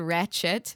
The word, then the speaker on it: Ratched: Wait, I don't know if it's R-A-T-C-H-E-D. I think I said Ratchet, but Ratched. – Ratchet. – It is Ratchet.